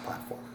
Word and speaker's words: PLT 4M: It's bizarre, the different combinations we get, but it's PLT 4M, and it's pronounced Platform.